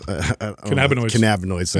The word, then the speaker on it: cannabinoids: Cannabinoids.